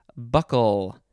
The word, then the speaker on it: buccal: Next is buckle. It is not the buckle that you use to put your seatbelt on or put a belt around your waist. It is spelled B-U-C-C-A-L. B-U-C-C-A-L. Buckle.